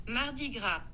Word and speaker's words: Mardi Gras: Mardi Gras.